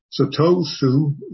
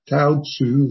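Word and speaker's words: Touzi: So Tosu is, or Tao Tzu, if you are more accurate using the Chinese pronunciation rather than the Japanese, is giving another facet to what Joshu's saying, not something different, just another way of expressing moment, moment, non-stop flow.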